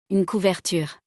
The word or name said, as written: Une couverture